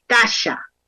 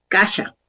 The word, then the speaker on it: Khasa: Kasha. Se escribe Khasa, Kasha.